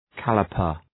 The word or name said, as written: calliper